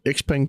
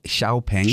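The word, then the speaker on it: XPeng: Jeg har den her X-Peng, kalder jeg den. Du kalder den Xiao Peng. – Det hedder Xiao Peng. – Xiao Peng. – Fordi X udtales Xiao.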